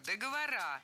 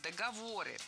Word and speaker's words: договоры: Вторая ошибка – договора. Более корректный вариант – договоры. При склонении ударение в слове «договор» сохраняем на третьем слоге. Договоры, договоров, договорами.